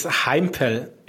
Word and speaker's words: Heimpel: It's Heimpel. It's Heimpel. German.